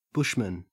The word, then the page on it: bushman: Australian English